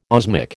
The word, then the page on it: osmic: American English